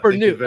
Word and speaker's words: Bernut: Laurent Bernut, but yeah, that was close enough. – Bernut, okay. Yeah, the T is silent.